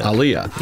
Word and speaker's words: Aaliyah: Aaliyah.